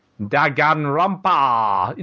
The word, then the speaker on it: Danganronpa: Yeah, I said Dan, didn't I? Sorry. Danganronpa. You know, actually, can I just say, on Kotaku, it is spelled D-A-N, Danganronpa.